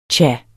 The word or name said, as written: tja